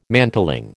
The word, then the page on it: mantling: American English